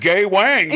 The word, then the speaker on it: Gawain: and his last name is spelled G-A-W-A-I-N. You pronounce it Gawain.